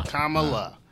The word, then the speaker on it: Kamala: Kamala. – Like, okay. Comma and then La. – Kamala.